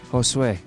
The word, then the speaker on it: Josue: Pronounced Josue, LOLs. – Thank you, Josue.